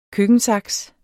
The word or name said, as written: køkkensaks